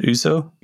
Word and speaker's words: ouzo: Is it ouzo? – Yep.